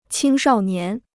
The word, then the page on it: 青少年: qīng shào nián